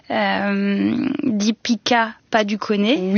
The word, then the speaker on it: Deepika Padukone: Deepika Padukone. – Oui. – Est-ce que c'est la bonne façon de le prononcer? – Oui.